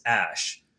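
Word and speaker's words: æ: there's actually a pronunciation for it, which is Ash. – Oh, wow. – Which was popularized by the Tesla guy. So, you know how his son or name is something, something, Ash, something. – Yeah, yeah. – So you can pronounce the A and the E together as Ash.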